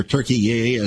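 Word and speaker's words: Türkiye: In other words, there's been no love lost between Mr. Erdogan, Mr. Netanyahu, between Turkey, or Turkiye, as they pronounce it now, and Israel.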